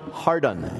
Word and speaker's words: Hardon: I imagine he pronounced it Father John Hardon, H-A-R-D-O-N.